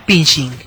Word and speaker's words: phishing: A este tipo de ataques se les llama phishing. Se pronuncia igual que phishing, pesca.